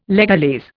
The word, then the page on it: legalese: Indian